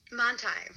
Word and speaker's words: Monthei: Did I say that right? – Montai. – Monty. There we go.